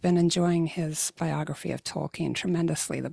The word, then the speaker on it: Tolkien: Yes, I'm saying it Tolkien, which is apparently the correct pronunciation. I've been saying Tolkien for many years, but was recently corrected.